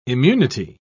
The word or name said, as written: immunity